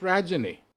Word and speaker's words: Rajani: What rhymes with Rajani? – Nothing. Absolutely nothing. But I tell people when they're confused about how to pronounce my name, imagine it rhyme. The first part rhymes with fudge.